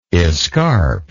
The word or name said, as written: escarp